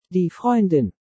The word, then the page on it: Die Freundin: German